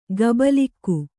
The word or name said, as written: ಗುಬಲಕ್ಕಿ